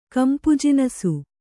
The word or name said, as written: ಕಂಪುಜಿನಸು